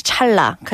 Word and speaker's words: 찰나: Because 찰나 is actually spelled 찰. And na, as you pronounced it, but then the pronunciation is 찰나, because when the lir meets the nian, the nian is pronounced like a lir.